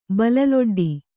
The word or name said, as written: ಬಲಲೊಡ್ಡಿ